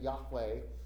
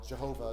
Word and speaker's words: Yahweh: I'm using the name Yahweh now. Jehovah is the old way of pronouncing it.